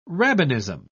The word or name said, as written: rabbinism